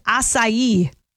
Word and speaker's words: acai: The number one most mispronounced word, though, is acai. Yeah, it looks like a K, A-C-A-I, like an acai bowl.